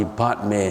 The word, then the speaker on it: Padme: Padme, Om Mani Padme Hum. P-A-D-M-E, if you pronounce it right, is Padme.